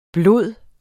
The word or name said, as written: blod